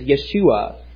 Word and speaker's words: Yeshua: The Hebrew pronunciation is Yeshua. We say Joshua.